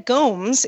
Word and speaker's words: Gomes: Uh, ben Gomes is my fiance's cousin. I just want to correct the pronunciation of his last name. That was me. It's pronounced like Holmes, not like Gomez, as in Gomez Addams.